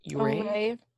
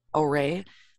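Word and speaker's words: Ouray: It's Uray or something. It's O-U-R-A-Y. – Uray. – Oray. Thank you.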